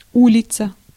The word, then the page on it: улица: /ˈu.lʲɪ.t͡sə/; ulitsa